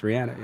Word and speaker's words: Rihanna: It's Rihanna.